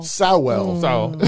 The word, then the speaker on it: Sowell: Sowell. It's my Texan right there. – Okay. – It's like oil.